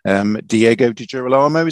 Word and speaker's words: Diego Di Girolamo: Diego Di Girolamo, is that right? – That's right, yeah. That's how they say it.